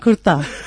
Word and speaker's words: kurta: You know, you've actually pronounced it the way you say his shirt. It's kutta and kurta. Kutta's a dog and kurta's a shirt.